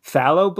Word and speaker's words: phthalo: with names like phthalo blue. Am I pronouncing that correctly? – Phthalo blue.